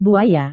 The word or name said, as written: buaya